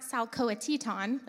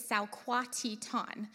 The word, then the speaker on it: Salquatitan: we figured out it's not Salcoatitan, like we thought, it's Salquatitan, so we did it like 10 times in a row just to get it in our brains, and some of us did better than others, so we're going to say it together, Salquatitan. Emphasis on the right syllable, people. Titan, okay? Salquatitan.